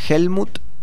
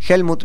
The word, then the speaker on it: Helmut: En redes sociales, en mi Instagram, salgo como Helmut Weigel, ¿sí? – Sí. – Helmut, mi nombre se escribe con H-H-E-L-M-U-T. – Sí. – W-E-I-G-E-L. – Bien. – Helmut Weigel.